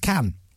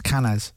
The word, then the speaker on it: Cannes: Now, why is it not pronounced Cannes? – Cannes, I don't know. – Right. – You don't pronounce the S in French, usually.